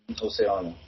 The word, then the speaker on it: Ocean: Sen är jag lite nyfiken på Marcus Liljus och Ocean. Visst uttalar man det så? – Ocean.